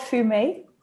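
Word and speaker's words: Fumé: It's called Fume. – Fume. – Yeah. – Which is spelled F-U-M-E with the little thing on top and then regular E.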